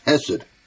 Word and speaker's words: Hesed: The word mercy um, in, in, in Hebrew means means uh, or, or is, is, it's pronounced Hesed, and it's found 251 times in the Old Testament, the scriptures.